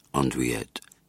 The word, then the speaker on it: andouillette: Andouillette, French pronunciation, andouillette, is a coarse-grained sausage made with pork, or occasionally veal, chitterlings, intestine, pepper, wine, onions, and seasonings.